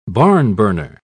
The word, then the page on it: barnburner: British English